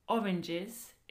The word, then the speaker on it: orange: And oranges is spelt exactly the same, just pronounced slightly different.